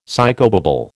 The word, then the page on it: psychobabble: American English